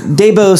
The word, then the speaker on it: Dabo: Headline number one, Dabo Swinney signs a new contract. – Old Dabo. – You pronounced correctly his name.